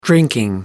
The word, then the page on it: drinking: American English